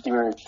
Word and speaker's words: Meshuggah: How do you say that? Demurge? – Demurge, yeah.